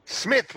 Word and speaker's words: Psmyth: Psmyth with a silent P. – That's right. Where's the P? Okay, thank you. – Before the S.